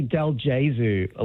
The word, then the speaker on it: Delgozu: It's actually Delgozu, like like Jesus, yeah.